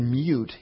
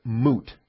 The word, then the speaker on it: mute: And so if you said mute, he would come down hard on you and say, no, it's moot. It's not pronounced mute, it's pronounced moot. And it's a dumb thing to be hung up on, because either pronunciation is acceptable depending on your dialect and region and so forth.